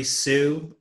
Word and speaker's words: Su: You guys can find me on Facebook, Jeffrey Sue.